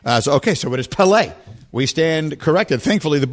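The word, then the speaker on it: Pelé: I always say Pele, and we just had a caller call in and say that I'm saying it wrong, that it's Pele. – Yeah, that's how I say it, Pele. – It's Pele. – On the second syllable.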